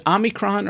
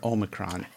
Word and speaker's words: omicron: Is it Omicron or Omicron? – I had to look up the pronunciation when I did my video. I think it's Omicron. – Omicron. – My friend of mine looked it up for me and said, you can say it either way.